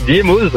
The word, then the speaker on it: Diémoz: Diémoz, on prononce le Z? – Eh oui, on le prononce, hein, bah oui, Diémoz.